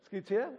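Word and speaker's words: Scuccia: Scutia.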